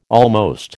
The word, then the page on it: almost: American English